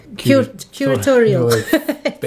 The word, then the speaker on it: curatorial: I don't even know how to say that. – Curatorial. – Based. – Yes. – That's how you say it.